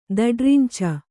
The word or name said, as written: ದಡ್ರಿಂಚ